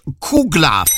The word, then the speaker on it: Kouglof: Anyway, the word is spelt K-O-U-G-L-O-F. It is pronounced Kuglaf. So the Kuglaf.